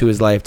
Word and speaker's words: WhoIsLyf: My name is Who Is Life, WHOISLYF.